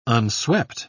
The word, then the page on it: unswept: British English